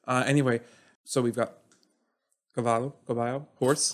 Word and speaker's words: cavallo: How do you say it in Italian? – Cavallo.